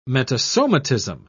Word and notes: metasomatism: British English

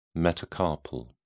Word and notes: metacarpal: British English

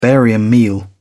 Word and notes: barium meal: British English

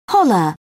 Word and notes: holler: British English

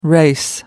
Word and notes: race: American English

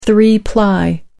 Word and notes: three-ply: American English

